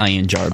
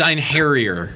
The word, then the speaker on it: Ein Harrier: Yes, there is our official pronunciation correction for... – I like Ein Jar better. – Now here's I can't tell you how many emails we got about that. – E-I-N tab. That's it. There's no way I could pronounce that. – Yeah, well, you know what? I'm not too embarrassed about our stab at the dark. – Do it again. – It's Ein Harrier, apparently.